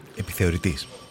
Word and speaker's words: Επιθεωρητής: Ο πανέξυπνος επιθεωρητάς δεν θα έπεφτε για κάτι τόσο απλό. – Επιθεωρητής. – Συγγνώμη. – Επιθεωρητής, όχι επιθεωρητάς.